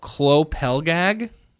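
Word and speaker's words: Klô Pelgag: I believe that the pronunciation of their name is Klopelgag, and I will not be able to pronounce the album, but I'm going to do my best.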